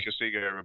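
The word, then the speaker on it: Kagiso: Consigo Rabada.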